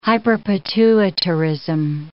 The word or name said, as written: hyperpituitarism